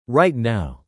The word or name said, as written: right now